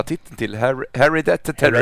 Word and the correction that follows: Hereditary: Hereditary. – Hereditary, ja.